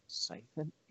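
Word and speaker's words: Chthon: Scython? Yes. – It's C-H-T-H-O-N.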